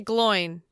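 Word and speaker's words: Glóin: Oh, speaking of pronunciations, how do you pronounce the name of Gimli's father? – You mean son of Gloin? – Yeah. So you say Gloin. – I say Gloin.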